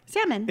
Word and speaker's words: salmon: You don't say the L. – Well, that's what, like, Salmon.